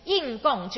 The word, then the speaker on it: 应供: 好，他又称为应供，这个不是应该的，应该的应要念成应，第四声应供，就是说受他应该可以受人道跟天道的供养，叫人天供养哦。